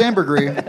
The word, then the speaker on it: ambergris: It is pronounced ambergris.